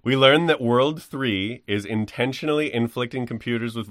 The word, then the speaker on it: WWW: During the events of the first game, the evil syndicate WWW, pronounced World 3.